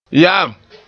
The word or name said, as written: ya'am